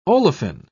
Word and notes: olefin: British English

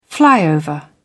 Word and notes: flyover: British English

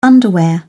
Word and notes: underwear: British English

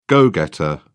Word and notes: go-getter: British English

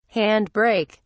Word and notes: hand brake: British English

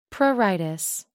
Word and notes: pruritus: American English

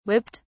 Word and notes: whipped: British English

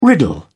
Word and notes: riddle: British English

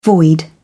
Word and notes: void: British English

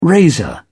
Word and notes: razor: British English

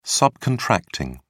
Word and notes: subcontracting: British English

